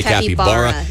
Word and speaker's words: capybara: I say capybara, they say capybara. – Yeah. – It's capybara, is how it's said.